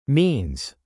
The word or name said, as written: means